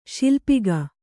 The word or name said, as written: ಶಿಲ್ಪಕ